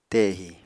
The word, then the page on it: tehi: apatani